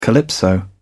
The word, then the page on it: calypso: British English